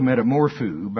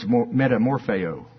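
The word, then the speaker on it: metamorphoō: Now, it's spelled metamorpho, but I get corrected. People that love me, they say, now, okay, yeah, it's spelled metamorpho, and that's cute. But it's metamorpheo.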